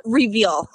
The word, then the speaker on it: Reveal: It's Reveal. I reveal to you last name. – That's what I thought it was. I didn't want to butcher it, though. I was like, it could be Revel. I don't know. – Nope, not Revel. It's okay. Reveal. Okay.